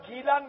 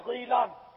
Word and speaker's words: گیلان: اس کی دو قراءتیں ہیں, دو تلفظ ہیں, جیلان بھی اس کا تلفظ ہے اور گیلان بھی, غین سے غیلان بھی اس کا تلفظ ہے.